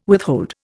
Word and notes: withhold: Indian

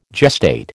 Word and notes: gestate: American English

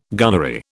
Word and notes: gunnery: British English

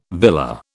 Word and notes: villa: British English

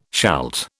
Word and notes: shalt: British English

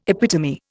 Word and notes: epitome: Indian

